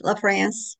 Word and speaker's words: LaFrance: Yes, LaFrance.